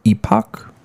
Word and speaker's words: Epoch: Epic? Is it E-P-O-C-H? – No. – It's definitely pronounced epic. – I see epic is E-P-I-C, so that's why I, you know. – There's two different kinds of epics. – Well, this epic is dumb. It's epoch.